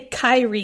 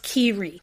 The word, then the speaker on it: Kyrie: I think that was my only issue with the book is I kept wanting to say Kyrie because it's K-Y-R-I-E, but it's pronounced Kiri.